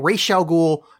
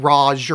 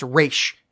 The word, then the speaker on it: Ra's al Ghul: Partially, this movie was made to annoy people that like Batman in the animated series because it's Carmine Falcone, not Falcone, and it's fucking Ra's al Ghul, not Raj or whatever the fuck they say it. It's Ra's. That is the fucking way you pronounce it.